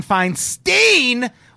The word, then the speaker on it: Feinstein: Max says, ATB, it's been driving me nuts when you call her Diane Feinstein when it's definitely pronounced like Einstein.